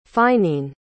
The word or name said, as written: fineen